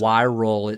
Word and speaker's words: Wiral: Wirral. It sounds like viral. – Viral. – Yes. Wirral. It's spelled W-I-R-A-L.